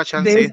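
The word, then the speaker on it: Khan: No, no, ah, estoy pronunciando su nombre bien. – Sí, Chaka Chan.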